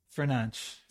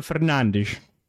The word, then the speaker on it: Fernandes: Bruno Fernandes. – That's how they say it. I think that's the Portuguese way, Spanish way.